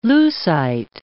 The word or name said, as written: leucite